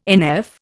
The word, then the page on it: NF: Indian